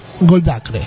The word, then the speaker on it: Goldacre: Vamos a contarte que vamos a tener, por ejemplo, en la obra de la semana, el libro Mala Ciencia de Ben Goldcrate, sí, Goldacre, ¿sí? Así se escribe. Mala ciencia, entonces, de Ben Goldacre, o Goldricri, o algo así. Vamos a decirlo bien, Ben Goldacre, ahí está.